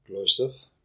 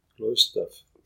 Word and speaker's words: Gloisdorf: Gloisdorf. – Sagen Sie es nochmal? – Gloisdorf. Gloisdorf. Das mit Ö.